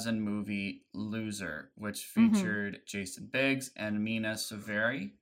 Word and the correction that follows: Sorvino: Is that how you say your last name? – No, Mina Sorvino. – Mina Sorvino, okay.